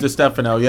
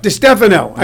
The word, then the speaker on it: DeStefano: Am I saying that correctly? – DeStefano, yeah. – DeStefano.